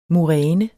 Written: muræne

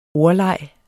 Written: ordleg

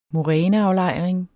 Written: moræneaflejring

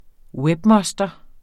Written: webmoster

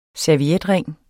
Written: servietring

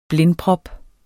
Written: blindprop

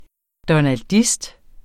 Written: donaldist

